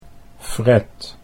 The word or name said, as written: Fred